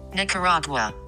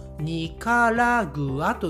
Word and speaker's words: ニカラグア: ニカラグアはい、えー、この国もですね発音は似ていますが、日本語ですとニカラグアと言います。ニカラグア。はい。そしてこのニカラグアの首都、キャピタルはこちらです。